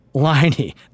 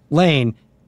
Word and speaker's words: Laine: His name is Patrick Laine, not Lane.